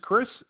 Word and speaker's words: Chris: First name is Chris, C-H-R-I-S. Last name is Davies, D-A-V-I-E-S.